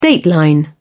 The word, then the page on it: dateline: British English